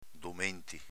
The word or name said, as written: domenti